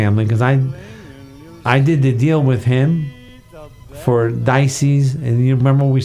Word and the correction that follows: Dieci: Am I pronouncing that right? – Dieci. Which means the number 10.